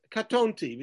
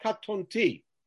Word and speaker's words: katonti: So, for instance, this word is katonti because the stress is on the tav, on the tet, because the trope mark is on the tet, katonti, and not katonti, which, by the way, would change its meaning.